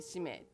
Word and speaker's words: 使命: ね、使う命と書いて使命って読むでしょ。